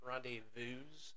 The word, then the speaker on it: rendezvous: I believe it's actually French. It's pronounced rendezvous.